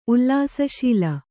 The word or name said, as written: ಉಲ್ಲಾಸಶೀಲ